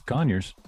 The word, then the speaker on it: Conyers: Did I say that right, Conyers, Georgia? – Yeah. Oh, it's Conyers. – Yeah, it's Conyers.